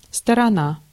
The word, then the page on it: phase: [ˈfeɪz]; American English